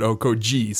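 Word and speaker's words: Okogie: And so that's something that Neil and I have always been like, is it Josh Okogi? Is it Josh Okoji? But either way, I was like, well, that one's on the media guide because it says to pronounce it Okoji.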